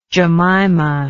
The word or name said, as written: jemima